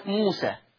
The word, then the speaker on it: موسى: احيانا بيجيني زي كلمة موسى، موسى دي بتتكتب ياء، بس هي اصلا بتتنطق ايه ولا تتنطق الف يا جماعة؟